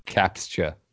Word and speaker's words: CAPTCHA: CAPATHA? – CAPTCHA. Captcha. Like capture? – I'm here to capture the flag.